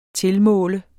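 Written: TILMÅLER

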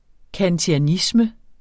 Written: kantianisme

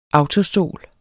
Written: autostol

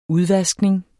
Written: udvaskning

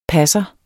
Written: passer

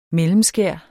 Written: mellemskær

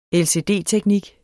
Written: LCD-teknik

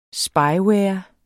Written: Spyware

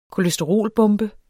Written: kolesterolbombe

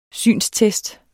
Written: synstest

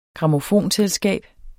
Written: grammofonselskab

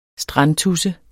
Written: strandtudse

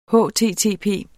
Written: http

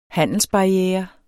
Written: handelsbarriere

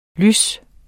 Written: lys

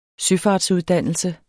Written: søfart

